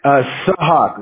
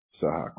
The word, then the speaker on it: Sahak: Sahak, like hawk, but Sahak, like that, yeah. – Okay. – Sahak.